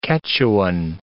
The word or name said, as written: Quechuan